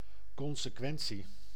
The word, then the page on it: consecuencia: /kon.seˈkwen.θja/